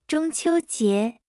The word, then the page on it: 中秋节: 0.8x